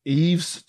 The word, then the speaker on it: Yves: Mine is Kerosene by Eve's Tumor. – What the fuck is that? I don't know what that is. – It's dope. – Eve's Tumor? – Yes, yeah, spelled like Y-V-E-S. – Y-V-E-S, let me see.